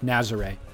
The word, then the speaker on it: Nazaré: Nazare is what we're calling it from now on.